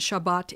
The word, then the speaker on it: Shabbat: Shabbos is Ashkenazi and Shabbat is the other way around. Ashkenazi and Shabbat is Sephardic.